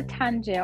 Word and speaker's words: Tangier: So I am going to, I think this is how you pronounce it, either Tangier or Tanger. I don't know. – Yeah, in Morocco. – I think in Arabic it's Tanger.